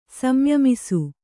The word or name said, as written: ಸಂಯಮಿಸು